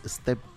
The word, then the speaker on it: Stapleton: Y bueno, sorprendieron ahí al mundo con una de las presentaciones, porque estuvo la señora Dua Lipa con el señor Chris Stapleton.